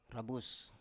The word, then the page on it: rabus: Biak